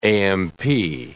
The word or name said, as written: AMP